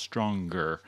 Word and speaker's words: stronger: Stranger has a soft G sound, stringer has a hard G, and stronger actually has 2 G sounds, stronger.